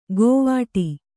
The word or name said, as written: ಗೋವಾಟಿ